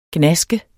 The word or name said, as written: gnaske